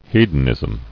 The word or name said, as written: Hedonistic